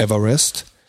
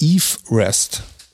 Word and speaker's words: Everest: Weil der heißt nämlich in Wirklichkeit gar nicht Mount Everest, sondern der heißt Mount Eve Rest. Weil dieser Nachname von dem Typen wird nämlich Eve Rest ausgesprochen ah. – und nicht Everest. So.